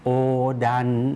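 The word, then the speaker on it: โ อดัน (横断): าลายนะครับในภาษาญี่ปุ่นก็จะใช้คำว่าโอดันโฮโดโอดันโฮโดมีคันจิอยู่4 ตัวนะครับเราออกเสียงช้าๆชัดๆนะครับก็จะออกเสียงว่าโออุครับคันจิตัวแรกนะครับออกเสียงช้าๆชัดๆจะเป็นโออุครับแต่ว่าคนญี่ปุ่นเสียงนี้นะครับรวบยาวไปเลยนะครับก็เลยออกเสียงว่าโอครับโอนะครับคันจิตัวต่อไปนะครับออกเสียงดังครับถ้าออกเสียงช้าๆก็จะออกเสียงว่าดะอึครับแต่เสียงนี้นะครับคนญี่ปุ่นก็ออกเสียงรวบยาวเหมือนกันดันครับออกเสียงลากยาวด้วยนะครับโอดัน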